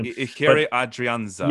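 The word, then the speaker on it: Ehire Adrianza: Adriana, I don't want to butcher his first name. – Ikeri Adrianza.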